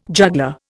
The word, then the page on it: juggler: Indian